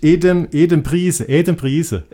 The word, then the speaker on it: Adam Price: Das ist kein Engländer, das ist ein Däne. – Ist ein Däne, aber ja, ich glaube, du darfst ihn trotzdem Adam Price aussprechen. Ja, der spricht sich Adam Price.